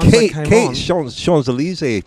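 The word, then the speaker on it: Champs-Élysées: But Champs-Elysees pronunciation there was spectacular. Fantastic. I hope you're not listening, Rob. – Yeah, you don't pronounce the P like, it's not champs like champion.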